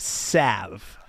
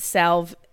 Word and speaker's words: salve: Is it salve or is it supposed to be pronounced salve? I'm not clear on that. – It's salve, but salve sounds more anti-boner to me. – All right. – It's salve for sure.